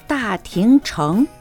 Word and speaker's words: 大庭城: 大場城は大庭城と言います。同じ漢字を書きます。